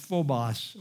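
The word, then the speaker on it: phobos (φόβος): The word fears is the Greek word pronounced phobos, or in English, phobia.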